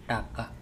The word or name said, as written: Taka